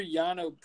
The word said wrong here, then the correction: Yiannopoulos: I butchered that name. – Yiannopoulos, okay. It was more simpler than I was making it.